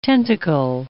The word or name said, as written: tentacle